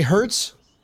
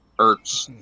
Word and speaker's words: Ertz: Did you say Hertz? – Ertz. Ertz is a moving company.